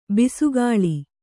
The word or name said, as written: ಬೆಸಕೋಲು